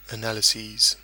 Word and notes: analyses: British English

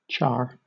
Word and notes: char: British English

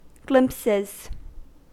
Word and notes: glimpses: American English

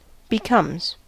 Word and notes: becomes: American English

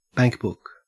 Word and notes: bankbook: Australian English

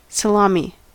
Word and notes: salami: American English

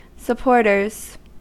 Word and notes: supporters: American English

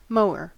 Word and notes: mower: American English

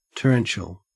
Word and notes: torrential: Australian English

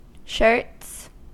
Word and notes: shirts: American English

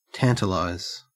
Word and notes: tantalize: Australian English

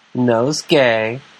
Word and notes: nosegay: American English

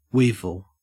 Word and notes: weevil: Australian English

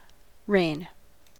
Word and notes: reign: American English

